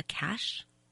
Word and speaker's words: cache: And then they fill it up during the wet season with water, and they would have these, what do they call it, C-A-C-H-E, is that a cache? How do you pronounce it? – Cache, yes.